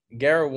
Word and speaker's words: Garrett: But so Garrett one T, two R's, one T.